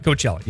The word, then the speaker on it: Coachella: Coachella. Am I saying that right? Coachella, yeah.